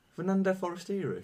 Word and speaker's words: Forestieri: You said his name wrong. – Fernando Forestieri.